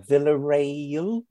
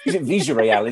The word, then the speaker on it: Villarreal: Villarreal. I've not heard of them before. – You still said it wrong, but it's fine. – is it Villarreal? Is it? – Yes.